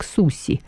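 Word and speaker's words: суси (すし): Слово «суши» в Японии произносится как «суси», в переводе «кислый рис».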